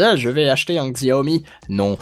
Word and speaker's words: Xiaomi: On prononce ça Xiaomi Xiaomi, ouais.